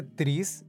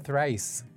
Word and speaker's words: thrice: Toda la gente dice tris y yo estoy seguro que se pronuncia thrice.